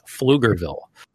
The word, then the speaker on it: Pflugerville: It's a silent P, Glenn told me. It's Pflugerville. Texas starts with a P, but it's not there. Pflugerville, Texas, like Price Pfister.